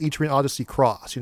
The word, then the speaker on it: Etrian Odyssey X: And technically, it's not even called Etrian Odyssey X. I believe it's actually supposed to be pronounced Etrian Odyssey Cross, you know, those wacky Japanese.